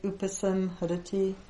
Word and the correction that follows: upasmarati: Oops, sorry. I always say that wrong. Chaitad upasmarati.